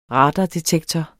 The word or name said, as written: radardetektor